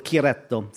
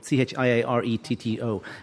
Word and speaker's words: Chiaretto: And Bardolino is like Valpolicella, but they also make rosé called Chiaretto, Chiaretto. And I'm pretty certain that is how you pronounce it.